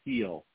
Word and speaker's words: Gil: Yeah, because I know that like the New England Revolution, for example, they have a player called Carlos Gil, but it's pronounced heel. – Yeah. – Like the heel of a shoe, or the heel of a foot.